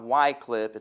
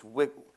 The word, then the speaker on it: Wycliffe: It's not Wycliffe. It's Wycliffe. I can't say it correctly.